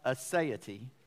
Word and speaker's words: aseity: The word is pronounced aseity. It is spelled A-S-E-I-T-Y.